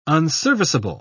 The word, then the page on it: unserviceable: British English